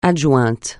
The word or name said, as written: adjointe